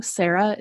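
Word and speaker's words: Serra: And again, just for those of you who are new, Sarah is spelled S like Sam, E-R-R-A, Dawn Sarah, first and last name.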